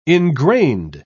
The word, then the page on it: engrained: British English